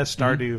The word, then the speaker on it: Stardew: Uh, did I uh, say the name right? Stardew. – I said it right. You said Yeah. Sardew. – I did not say Sardew. – Yeah, It's did. Accent on the O.